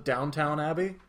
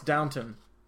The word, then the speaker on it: Downton: Do you pronounce it Downtown Abbey? – Yeah. Is that not what it is? – No, it's Downton.